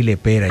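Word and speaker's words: Le Pera: Entonces, Gardel y Lepera. Yo, no era Lopera, lo he dicho mal toda la vida.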